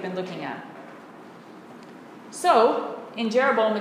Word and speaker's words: Jeroboam: Now this is the same verb used of Yoroboam. Yoroboam. Jeroboam. Mixing Hebrew and English pronunciations.